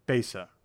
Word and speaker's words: Besa: So, please talk about, I don't want to butcher it because, you know, Besa.